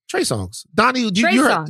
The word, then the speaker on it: Trey Songz: Trey Songz.